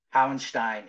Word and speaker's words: Hauerstein: Hauerstein. Yeah, you even did the little German inflection there. Nice job.